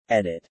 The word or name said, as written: edit